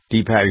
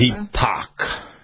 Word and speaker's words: Deepak: Deepak is what we say in the room. – I always heard, yeah, Deepak Chopra. – Deepak.